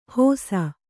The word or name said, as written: ಹೋಸ